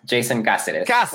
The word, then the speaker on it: Jason Caceres: You're going to have to say your last name because I don't want to mess it up. – Jason Caceres. – There we go.